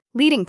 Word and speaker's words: leading: Leading, pronounced leading, named after the lead strips used in traditional typesetting, is the vertical space between lines of text.